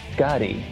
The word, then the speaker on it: Schkade: It's pronounced Scotty. It's like Scotty if you had kind of a load on.